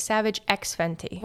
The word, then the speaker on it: Savage X Fenty: This is where you don't say the X, right? It's Savage X Fenty.